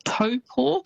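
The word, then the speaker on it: Taupō: but famous spots including Taupo. So quite often you'll hear that said as Taupo. – Yes. – It's almost how it's spelt, but that Taupo is actually pronounced Taupo.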